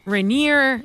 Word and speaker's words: Rainier: And then you see Mount Rainier.